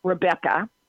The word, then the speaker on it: Rebecca: and then it's Rebecca, and it's spelled R-E-B Rebecca Austill, Austill, Clausen, Clausen.